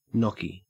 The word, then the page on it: gnocchi: Australian English